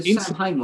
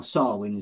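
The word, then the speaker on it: Samhain: The Sam Hain one that was last was fantastic. Or Sarwin, as it's pronounced.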